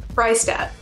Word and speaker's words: Fraistat: Fraistat.